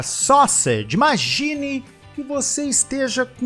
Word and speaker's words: sausage: Só sede. Soa como sausage. Sausage.